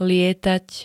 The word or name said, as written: lietať